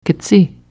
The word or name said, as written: ktsi